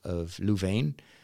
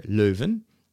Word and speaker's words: Leuven: Which is kind of the French counterpart of the old University of Leuven, or Leuven, which is Dutch speaking.